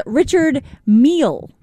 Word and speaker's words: Richard Mille: No, I steered you wrong, Dale. It is Richard Mille.